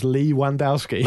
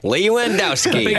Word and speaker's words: Lewandowski: Phil Pierce also says, my highlight was a friend of mine actually pronouncing Lewandowski's name as Lee Wandowski. – Nice. – Lewandowski.